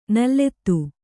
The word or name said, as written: ನುಲಿತ